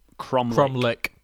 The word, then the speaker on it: cromlech: And it was cromlech. – Cromlech. – Yep. – Which is an unusual spelling. It's C-R-O-M-L-E-C-H. Cromlech. – Oh, cromlech. Crom. – But the pronunciation is spot on because I did check it.